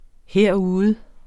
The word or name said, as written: herude